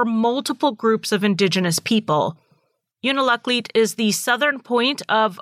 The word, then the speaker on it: Unalakleet: A lot of people pronounce the name of this town as Unalakleet and leave out the middle syllable, which is also an acceptable way of saying that.